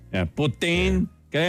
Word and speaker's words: poutine: How do you pronounce poutine? Poutine or poutine? Well, I'm from Ontario, so I say poutine.